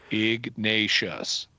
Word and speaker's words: Ignatius: Ignatius.